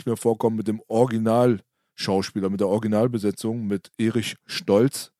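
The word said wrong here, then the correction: Eric Stoltz: der Eric Stolz. Genau, der wird natürlich so ausgesprochen dann.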